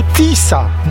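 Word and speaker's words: Tisza: Po węgiersku Tisa, nazwa pisana przez SZ, które wymawia się jako S.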